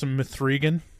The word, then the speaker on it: M3gan: I believe it's pronounced M3gan.